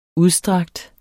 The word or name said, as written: udstrakt